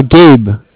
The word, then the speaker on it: Gabe: It's Gabe. – Spell it. – G-A-B-E. – G-A-B-E? – Yeah, like Gabriel.